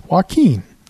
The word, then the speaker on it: Joaquin: It's Joaquin.